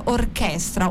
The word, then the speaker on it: Orchestra: la Shoot Orchestra o Orchestra, non so, pronunciano un po' tu come vuoi. – Sì, no, no, direi orchestra, okay. facciamo gli italiani.